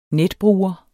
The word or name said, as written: netbruger